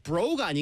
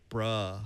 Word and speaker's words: bro: bro가 아니고 bro. – bro. 이런 느낌 아니에요. 실망이니까 이 R 발음 아 나랑 오래 했잖아 이제 R 발음 좀 냅시다.